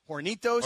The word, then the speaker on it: Hornitos: Hornitos. – Hornitos. – Si. – Yeah. – Should I not pronounce the H? – I mean, it's up to you.